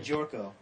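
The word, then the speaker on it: Gyorko: Now you pronounce his last name. Jorko. – Jed Jorko of West Virginia.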